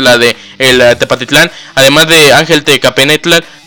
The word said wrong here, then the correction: Tecapenatl: Tecapenatl, perdón, Tecapenatl, una disculpa por la pronunciación del nombre.